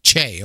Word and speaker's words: Che: I don't know if I am pronouncing his name right or not, but Michael Che, is it pronounced from Saturday Night Live? – Michael Che. – Che, okay.